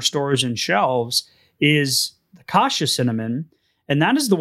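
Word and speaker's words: cassia: So kasha cinnamon, it's kind of like kashi, but it's in pronunciation, but not an E at the end, an A. So kasha, yeah. It's C-A-S-S-I-A. And then Ceylon cinnamon, C-E-Y-L-O-N.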